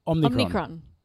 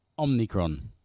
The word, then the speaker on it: Omicron: Omicron. Omicron. – Doesn't roll off the tongue, does it? Let's hope it doesn't have to remain there for that long. – I've got Omicron fatigue.